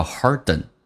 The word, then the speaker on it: harden: 来看一下 hard 跟 ant 有一个零，叫 harden， ant 可以发成 n，然后 t 在美式发音要浊化，叫 harden， harden mind，